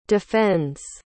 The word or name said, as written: defends